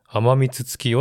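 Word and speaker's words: 天満月: 天が満ちる月って書いて、甘蜜月よって読ませるんですね。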